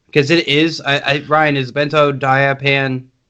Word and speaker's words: benzodiazepine: Yeah, it's a benzodiazepine. Is that saying that right? A benzodiazepine? Yeah. And what a lot of people... Yeah, that's the word.